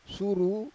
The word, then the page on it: suru: Roon